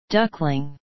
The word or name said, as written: duckling